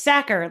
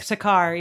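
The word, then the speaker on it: Sachar: it's Sacker, like a sack or like in football, not like Sakar, even though it's spelled Sakar. Holes.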